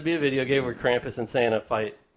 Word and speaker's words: Krampus: Well, if you believe in Santa, you got to believe in Krampus. – Right, exactly. Which is metal as fuck. – Did I say it right? – Krampus. – Yeah, I got it.